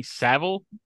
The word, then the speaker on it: Savile: Well, Metal Mickey made an appearance on infamous child predator Jimmy Savile.